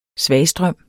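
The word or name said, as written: SVAGSTRØM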